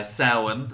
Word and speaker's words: Samhain: Samhain, as it's pronounced, which just means November.